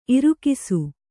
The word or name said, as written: ಇರಿಕಿಸು